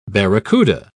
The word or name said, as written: barracuda